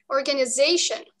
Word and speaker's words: organization: В английском языке organization, organization. Mm-hmm. Твой pitch или ударение падает на вот это, на слог they. Он длиннее, и, опять же, меняется тональность. И звучишь ты по-другому.